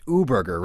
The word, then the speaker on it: U-Burger: You are the expert, but it is our show, is that U-Burger, which I'm going to go ahead and say is pronounced U-Burger, right? – Okay. – Because it's a play on the German word Uber.